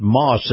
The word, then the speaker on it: Maus: Now, this portion of the program brought to you by our friends at Moss, that's spelled M-A-U-S, Family Automotive, and all those Moss Nissan dealerships.